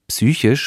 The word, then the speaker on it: psychisch: Psychisch spricht man so aus. Psychisch.